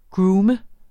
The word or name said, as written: groome